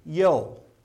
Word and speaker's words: Joel: And so the way you would pronounce his name in Hebrew, Joel, would be Yol.